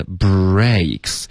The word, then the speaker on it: breaks: 然后后面呢 breaks 这个字其实它的发音有一点难，大家要注意这个，你爆破一个 b 的音呢，要直接进入到一个 r，有一个 r 的音，OK， br， OK，是一个融合音，bra，嘴巴再张开到一个 a，然后再咬下去，bray， OK，很多的学生会把这一部分呢发成 back。